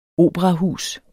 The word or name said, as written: OPERAHUSET